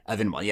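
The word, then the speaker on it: viennoise: It's venoise, isn't it? – Venoise, yeah, exactly.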